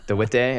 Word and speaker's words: DeWitte: I've heard her say it, so it's like DeWitte, yeah. It's not how I said it, basically, anyway.